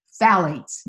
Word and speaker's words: phthalates: Number three heavy hitter is phthalates. Now I'll spell that one for you because it's a little tricky. It's P-H-T-H-A-L-A-T-E-S. Phthalates. The P-H is silent.